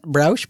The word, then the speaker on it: Browsh: Well, to keep us on the text-based train, Joey over at OMG Ubuntu calls out Browsh, I guess is how you say it. Browsh, B-R-O-W-S-H.